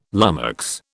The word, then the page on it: lummox: British English